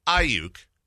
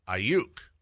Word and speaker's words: Ayuk: Okay, so Ayuk or Ayuk. – Gotcha. – You know, with the emphasis. I thought it was the emphasis on the Uke. – Not on the I? – Not on the I. The Uke, as in you can't win the ball game if your quarterback is stepping out of the end zone.